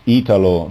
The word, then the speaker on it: Italo: Actually, in Italian, it's Italo, so you have to put the accent on the starting I.